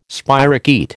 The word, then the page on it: spirochaete: American English